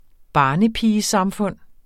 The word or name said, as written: barnepigesamfund